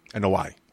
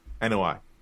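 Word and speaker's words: Anoa'i: Anua family? – No. – Oh, fuck. How do you say it? – NOI. – NOI.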